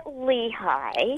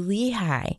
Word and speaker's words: Lehigh: We say it Lehigh. – Oh, you say Lehigh. – Yeah, they pronounce things differently here, but you're in Pennsylvania, right? – I'm originally from Kansas City.